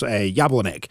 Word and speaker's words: Jablonec: Celtic playing LASK on Wednesday, and Rangers Jablonec.